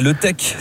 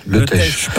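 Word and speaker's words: Le Teich: Vous êtes propriétaire d'un camping à Letech, c'est ça, sur le bassin d'Arcachon. – Letech.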